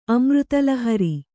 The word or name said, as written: ಅಮೃತಲಹರಿ